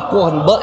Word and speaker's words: Korn: Korn.